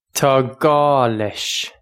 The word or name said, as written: Tá gá leis